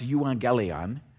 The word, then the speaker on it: euangelion: And the word that's translated good news there is a Greek word that's just pronounced euangelion. It's where we would get our word evangelism.